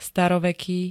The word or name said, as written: staroveký